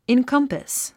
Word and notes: encompass: inkʌ́mpəs; インコンパス

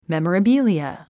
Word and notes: memorabilia: mèm ə rəbíliə; メモラビリア

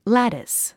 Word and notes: lattice: lǽtis; ラティス

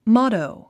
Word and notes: motto: mάtou; モォットォ

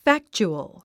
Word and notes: factual: fǽktʃuəl; ファクチュアル

